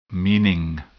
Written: meaning

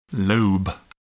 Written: lobe

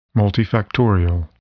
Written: multifactorial

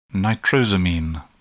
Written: nitrosamine

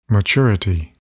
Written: maturity

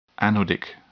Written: anodic

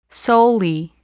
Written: solely

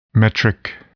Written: metric

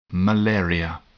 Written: malaria

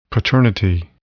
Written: paternity

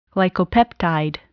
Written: glycopeptide

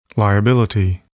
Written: liability

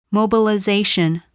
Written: mobilization

